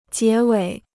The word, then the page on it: 结尾: jié wěi